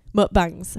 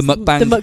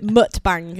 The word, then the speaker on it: mukbang: The mukbang. Does that mean when you blow your load as you're pulling out the arse? – Or you could pronounce it wrong and call it mutbang, and it's like a whole new level of banging dogs or some shit.